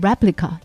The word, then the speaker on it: Replika: 它的名字和英文 replica，也就是复制品，有着一样的发音。用户可以选择 Replica 的名字、性别和外观，输入详细信息之后，就可以和他聊天了。聊天越多，Replica 接收到的信息就越多，也就会越像使用者。 Replica 的用户在接受《纽约时报》采访的时候说：“ 我知道这是一个人工智能，它不是一个人。